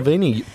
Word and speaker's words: Sevigny: Chloe Sevigny, bang on the money.